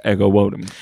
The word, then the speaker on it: Ego Nwodim: And then I texted you guys a video of them pronouncing it, and it is Ego Wodum.